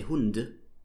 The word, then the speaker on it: Hund: So you write Hund with a D, but you would never say Hund, you say Hund. So you spell it with a D, but you speak it with a T.